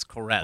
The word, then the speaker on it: Koren: I don't know if I still have the same one, but certainly we all have our Koren, and I've learned how to pronounce it correctly. It's Koren.